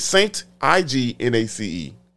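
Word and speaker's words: St. Ignace: Is it Ignacy or Ignacy? It's Saint Ignace.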